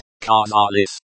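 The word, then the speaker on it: Kazaris (казались): Pronounced, Kazaris, car, as in, car, zar, as in, zap, and, lis, as in, list, means, seemed, and is the past tense of the verb, to seem, kazaresya, in the plural form.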